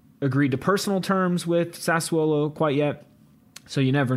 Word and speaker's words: Sassuolo: Sassuolo.